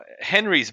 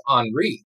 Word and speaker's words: Henri: Well, even with the common names like this year, Henry's back, but of course we all know it's not pronounced Henry. – That's true. – I didn't know that. Boy, it's a good thing you guys had me on, or else I would have been mispronouncing that. – You see, it ends in an I, so we're going with Henri.